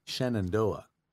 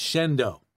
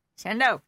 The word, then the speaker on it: Shenandoah: I had a girlfriend that lived in a town that is written on paper, Shenandoah. If you go into this town and pronounce it Shenandoah, they're going to follow you around and see you wait till you leave. – Yeah. – It's Shendo.